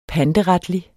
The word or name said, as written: panteretlig